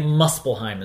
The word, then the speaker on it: Muspelheim: Muspelheim is how it's pronounced.